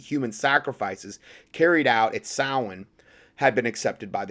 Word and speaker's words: Samhain: And it looks like it's pronounced Samhain, but it's actually pronounced Samhain.